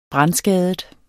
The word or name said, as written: brandskadet